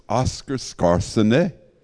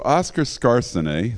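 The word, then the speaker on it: Skarsaune: And so the way you pronounce his name is Oscar Skarsene. And so Oscar Skarsene, that's how we say it,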